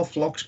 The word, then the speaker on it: Pflock: And if your listeners are unfamiliar with it, Carl Flock's name is P-F-L-O-C-K. So it's Flock, like with a silent P, like the German spelling of it.